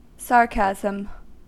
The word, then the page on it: sarcasm: American English